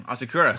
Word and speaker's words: Asakura: Asakura. – Koi Asakura.